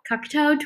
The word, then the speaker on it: Cocteau: Cocteau Twins. They're Scottish, but with a French name.